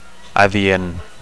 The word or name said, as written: adient